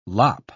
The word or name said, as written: lop